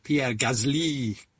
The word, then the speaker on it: Pierre Gasly: I know on Thursday we had Pierre Gasly, got to pronounce it properly, haven't you? And well done.